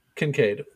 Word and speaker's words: Kincaid: One second though, the only guns that do work are fired by Concaid. If he fires a weapon, then you know that it's going to do some damage. – Kincaid. – What? I got the name right, man. Okay, why are you messing with me here? – We're slowly improving your pronunciation.